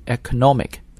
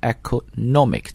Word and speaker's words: economic: 它呢跟这个经济 economy 是一个重音的位置，但是呢如果我们要把它读成经济学或者经济的这个单词呢应该读 economic, 那么重音呢 economic 在 nomic 上面，所以一定要注意这个重音的迁移。我们再来一遍，经济 economy, 经济学家 economist, 经济的 economic, 啊，这几个词一定要有一定变化。